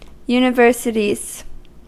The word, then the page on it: universities: American English